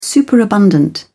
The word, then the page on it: superabundant: British English